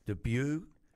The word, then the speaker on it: debut: I reckon the shout-out of the week has to be the debut. How do you say it? D-E-B-U-T. – Debut, that's it.